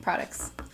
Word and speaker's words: Produx: pronounces it like that though, but if you say it really fast, it sounds like products.